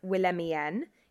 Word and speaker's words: Willemien: And also, there is a brand called, I think it's pronounced Willemien. It's like Wilhelmina, but I-E-N at the end instead of Ina.